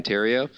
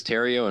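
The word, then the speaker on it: Theriot: But it's also really cool just because Terrio and Fontenot had such a history of playing across from each other. You know, their names rhyme. They're both from they're both silent T's and all that.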